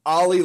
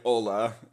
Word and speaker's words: Olle: Okay. Ollie Lixell. – Everybody says Ollie. – Ola, yeah. – Well, I remember back at the beginning of camp when he was starting to turn heads a little bit, and we all pronounced it like Oli Jokinen, so we said Oli Lixell. You know, it's Ola, yes, but nonetheless.